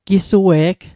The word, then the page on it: gisue'g: Mi'gmaq